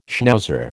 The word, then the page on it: schnauzer: American English